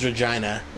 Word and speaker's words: Regina: They didn't believe us that how you pronounce that name is Regina.